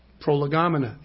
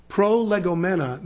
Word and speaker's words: prolegomena: It comes from a Greek participle pronounced prolegomena, prolegomena in English. You know what it means? Prolegomena means the things being said beforehand.